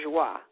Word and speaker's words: Joie: She changed the pronunciation to Joie when she started working. Yeah, it looks like Joey on paper, but it's pronounced the French pronunciation Joie.